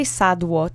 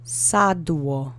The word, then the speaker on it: sadło: The accent is on the first syllable. Sadło. Sadło.